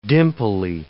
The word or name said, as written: dimply